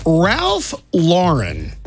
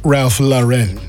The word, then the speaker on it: Ralph Lauren: And Ralph Lauren, that's how I pronounce his name, not Ralph Lauren.